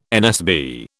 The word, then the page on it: NSB: British English